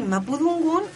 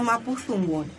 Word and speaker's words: mapudungun: En algunas zonas se pronuncia du y en otras se pronuncia más bien fu. Ya, por ejemplo, mujer se dice domo o fomo. Entonces tú puedes decir mapudungun o mapuzungun.